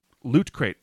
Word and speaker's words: Loot Crate: Loot Crate, LOOTCRATE. – Oh, loot. – Loot. You're getting loot. You're getting cool stuff. – Not what I thought you said. – And of course, by supporting Loot Crate, you support this podcast.